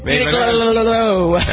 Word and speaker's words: Uniqlo: Am I pronouncing that right? Yeah, that too. – Uniqlo.